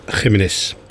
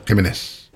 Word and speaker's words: Jiménez: Flaco Jiménez. Zo moet je het uitspreken, hè, geloof ik. – Ja, Jiménez. – Jiménez.